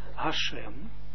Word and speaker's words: Hashem: Wat hem had wekim Be'ashem, ook hier moeten we uitspreken als Hashem.